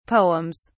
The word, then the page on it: poems: British English